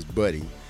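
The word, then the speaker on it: Budde: And Ed, I think I just said his name wrong. Ed, not Booty, is Buddy.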